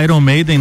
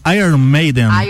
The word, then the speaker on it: Iron Maiden: Sabia que o nome da banda Iron Maiden não se fala Iron Maiden? – Não. Se fala Iron, Iron Maiden. Iron. Iron, a pronúncia Iron. de Iron, ferro, né? Iron Maiden.